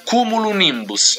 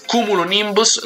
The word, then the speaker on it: Cumulonimbus: Spacing Out è il nome dell'album, e il pezzo che ci ascoltiamo è Kumolin. Questo è difficile, anche se non è giapponese, ma non ci riesco a pronunciarlo. Si chiama Cumulonimbus. Ecco, ce l'ho fatta. Cumulonimbus, lo ridico, Shigeharu Mukai.